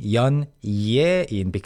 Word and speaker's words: 연예인: And again, please mark the spelling 연예인 because it's from 예술. But people actually pronounce it as 연예인.